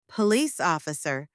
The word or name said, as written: police officer